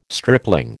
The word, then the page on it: stripling: American English